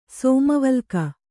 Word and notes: ಸೋಮವಲ್ಕ: kannada

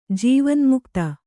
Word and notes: ಜೀವನ್ಮುಕ್ತಿ: jīvanmukti

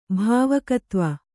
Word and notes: ಭಾವಕತ್ವ: bhāvakatv

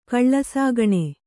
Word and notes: ಕಳ್ಳಸಾಗಣೆ: kaḷḷasāgaṇe

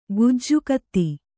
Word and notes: ಗೆಜ್ಜೆಗುದಿ: gejjegudi

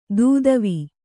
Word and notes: ದೂದವಿ: dūdavi

